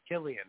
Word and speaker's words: Cillian: No, did I say it right? Cillian or Killian? – I think it's Cillian. – Cillian. – Killian, okay.